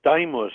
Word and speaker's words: Daimus: This morning, southeast Spain, a city called Daimus, I believe it is pronounced, D-A-I-M-U-S.